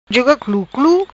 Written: Cluclu Juga

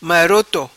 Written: Maroto

